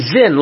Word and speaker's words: Sin: Because I believe the proper pronunciation would be Zin, like with a Z.